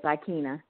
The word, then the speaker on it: Ikenia: Yes, Ikenia, yes.